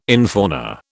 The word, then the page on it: infauna: British English